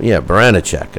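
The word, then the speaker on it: Baranchyk: On Showtime Friday night, Ivan the Beast Baranicek, Baranicek is the way I pronounce it. I think they were calling it something else on the broadcast.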